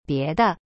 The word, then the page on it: 别的: bie de